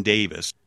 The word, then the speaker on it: Davies: The name of the new character will be Gwyn Davis. I think it's actually spelled D-A-V-I-E-S, but the kind of the British or Welsh way of pronouncing it is Davis, like Ray Davis of the Kinks.